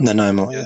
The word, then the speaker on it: Nanaimo: Nanaimo, Nanaimo.